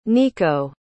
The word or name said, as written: Nico